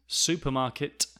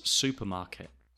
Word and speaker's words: supermarket: Also, notice that a lot of English speakers do not pronounce the T at the end of words. Supermarket becomes supermarket, supermarket, supermarket, supermarket.